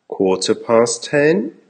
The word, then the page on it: quarter past 10: American English